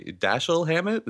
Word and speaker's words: Dashiell Hammett: and was based on a novel written by Dashiell Hammett. Is that what we're going with? – That's always been my understanding of pronunciation.